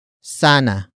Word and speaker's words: sana: The next word is sana. Sana. Sana. Sana is spelled as S-A-N-A.